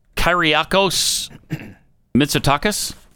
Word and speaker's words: Kyriakos Mitsotakis: Kyriakos Mitsotakis? – Oh, yeah, sure.